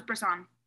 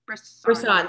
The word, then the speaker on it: Brisson: How do you say his last name? – I thought it was Brisson. – Brisson.